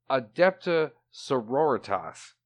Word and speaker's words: Adepta Sororitas: It is not Adeptus Sororitas. I have been mispronouncing it this whole time. It is Adepta Sororitas.